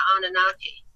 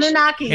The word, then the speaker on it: Anunnaki: And uh, Daena and I ascribe a little bit more to the Sumerian legends of the Anunnaki mm-hmm. Anunnaki. My pronunciation. – Anunnaki.